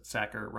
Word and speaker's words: Sachar: Yeah, but I found a short bit of audio of Lewis Sachar mentioning how his own name is pronounced, and he says that Sachar rhymes with cracker.